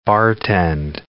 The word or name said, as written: bartend